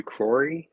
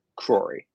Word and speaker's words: Crory: My name is Matthew Crory. It's like Corey, but with an extra R. Crory.